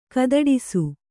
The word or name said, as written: ಕೈದೊಡಿಸು